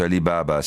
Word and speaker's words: Alibaba: Allora, su Alibaba o Alibaba, mi state segnalando che si pronuncia Alibaba senza l'accento, avete ragione, immagino, viste le segnalazioni.